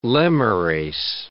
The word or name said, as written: lemures